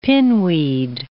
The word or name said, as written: pinweed